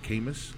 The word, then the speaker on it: Camus: Albert Camus. – Camus? – C-A-M-U-S.